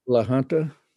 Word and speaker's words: La Junta: La Junta.